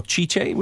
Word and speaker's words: Tite: Tite. Yeah. – Or Chiche, would it be pronounced? – Yeah.